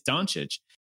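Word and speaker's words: Dončić: And Doncic? – Doncic. – Do I still say it wrong? I do. Okay. It's Doncic. Thank you.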